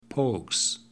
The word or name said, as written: pogues